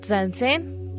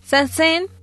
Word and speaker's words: Zazen: Y bueno, Zazen, como se pronuncia, es una mezcla entre lo francés y lo inglés en su pronunciación.